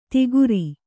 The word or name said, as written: ಧಕಾರ